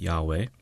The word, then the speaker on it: Yahweh: And it's pronounced, as I understand it, Yahweh.